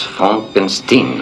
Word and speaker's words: Frankenstein: Mon nom, monsieur, se prononce Frankenstein.